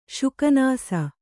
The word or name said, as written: ಶುಕನಾಸ